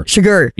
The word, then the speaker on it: Chigurh: Chigurh.